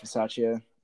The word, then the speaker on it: Bisaccia: And did you see I'm going to butcher his name, but Rich Bisaccia, whatever? – Yeah, Rich Bisaccia.